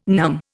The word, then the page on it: NUM: Indian